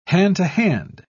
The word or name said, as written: hand-to-hand